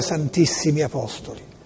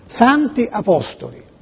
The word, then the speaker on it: Santi Apostoli: Ora, attenzione, Borgo Sant'Apostoli si scrive SS Apostoli. Molti dicono Santissimi. È sbagliato. Io vorrei dire anche a quella gente della RAI toscana, no? Molte volte l'ho sentito giorni fa, dire in via Santissimi Apostoli, anzi Borgo Santissimi Apostoli. Ma ragazzi, quando ci sono due S, è per il plurale degli apostoli.